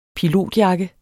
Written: pilotjakke

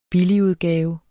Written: BILLIGUDGAVE

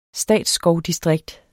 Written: statsskovdistrikt

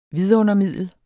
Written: vidundermiddel

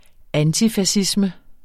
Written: antifascisme

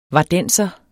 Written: vardenser

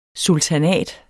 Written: sultanat

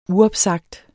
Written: uopsagt

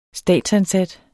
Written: statsansat

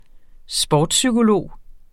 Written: sportspsykolog

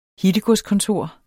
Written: hittegodskontor

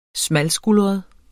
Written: smalskuldret

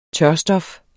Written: tørstof

